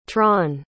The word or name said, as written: tron